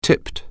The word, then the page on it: tip: anglais